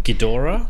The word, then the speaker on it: Ghidorah: Ghidorah? – Ghidorah, that's it.